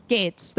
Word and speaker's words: Gaetz: Gaetz, the Republican in Florida.